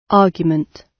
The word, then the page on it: argument: British English